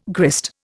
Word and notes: grist: Indian

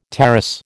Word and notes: tarras: American English

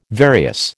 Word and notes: various: American English

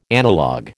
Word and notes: analog: American English

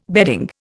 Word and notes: bedding: Indian

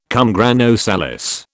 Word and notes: cum grano salis: British English

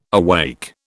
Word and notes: awake: British English

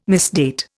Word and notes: misdate: Indian

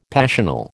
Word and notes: passional: American English

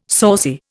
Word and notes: saucy: Indian

